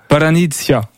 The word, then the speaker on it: Palianytsia: Bon, la prononciation exacte pour... – pour Paranitsia. – Paranitsia, voilà, comme ça.